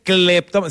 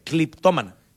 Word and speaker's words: cleptómana: No, pero feo, no se dice cleptómana, se dice cliptómana. – ¿Cliptómana? – Cliptómana. – ¿Es cleptómana? ¿Sí? No, es cleptómana, sope.